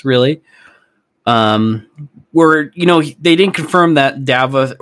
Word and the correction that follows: Davoth: Turns out I was saying it wrong the whole time. I think it's pronounced Davith. Davith? Davoth? Davoth? Davoth. That's what it is.